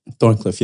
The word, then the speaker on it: Thorncliffe: Is it Thorncliffe? – Thorncliffe, yeah.